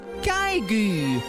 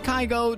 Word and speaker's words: Kygo: Het is wel grappig, want volgens Wikipedia spreek je zijn naam uit als Kygo. En ik dacht, nou, dat kan toch niet zo zijn dat we al jarenlang zijn naam verkeerd uitspreken. Dus ik heb het even opgezocht en in een interview zegt hij zelf ook, hi, I'm Kygo, dus het is wel echt Kygo.